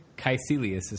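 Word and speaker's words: Kaecilius: But when, like, is it Caecilius? I always say his name wrong. Caecilius, is that his name? – Caecilius is how they pronounce it. Caecilius, excuse me.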